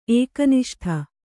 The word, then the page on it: ಏಕನಿಷ್ಠ: Kannada